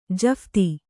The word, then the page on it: ಜಫ್ತಿ: kannada